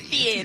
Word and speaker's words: Fieri: Fieri. You have to pronounce it properly.